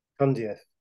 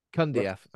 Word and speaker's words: Cundieff: How do you say his surname? – Kundieff. – Kundieff.